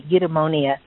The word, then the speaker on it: Eudaimonia: So I did say it right, Eudaimonia.